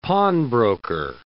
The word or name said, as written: pawnbroker